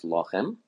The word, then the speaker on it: lechem: Notice that the word lechem has an esnachta, and that's why it's pronounced lochem.